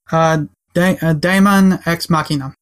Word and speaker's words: Daemon X Machina: Daemon X Machina. – Daemon X Machina.